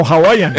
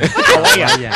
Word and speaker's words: Hawaiian: No, Hawaiian. Hawaiian. Al principio lo dijo bien.